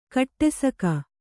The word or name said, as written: ಕಡುಸೊಗ